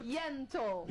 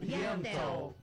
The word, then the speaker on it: Yentl: Ó, a querida Alessandra Maestrini, ela tá em peça, ela tem peça, que é Ente. Ente in Concert. – Yentl. – Yentl. Perdão, eu vou fazer com pronúncia Yentl in Concert.